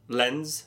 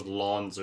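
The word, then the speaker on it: Lens: Lenz scoring. And that is how you say his name. I thought it was Lons or some, you got to roll your tongue. Yeah. But it's not. It's Lenz.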